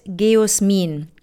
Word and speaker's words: Geosmin: G-E-O, Geo, dann ein S und dann M-I-N. Wie würdest du das aussprechen? – G-O-S. – G-E-O-S, M-I-N. – Geosmin. – Geosmin, okay, dann habe ich es richtig ausgesprochen.